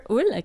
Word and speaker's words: Hull: Hull, je sais pas pourquoi je prononce l'âge comme ça. Non, Christopher Hull, qui réside à Stan Daniels depuis un an.